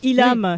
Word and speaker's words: Ilham: Donc Ilam, oui. Ça s'écrit I-L-H-A-M, Blues Band.